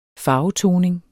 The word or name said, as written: farvetoning